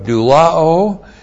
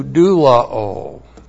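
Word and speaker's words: doulao (δουλόω): This is the aorist passive indicative of doulao, plus the dative singular of dikaiosune with the definite article. So we go from eleutherao to doulao. D-O-U-L-O, long O. Again, notice the A-O ending. Doulao.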